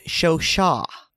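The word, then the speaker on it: Chauchat: So according to the Big Book of Gun Trivia, everything you want to know, it is pronounced Shosha.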